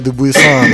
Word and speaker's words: Dubuisson: You know, I used to say Dubuisson, like in French, you know, but I tried to Englishize it.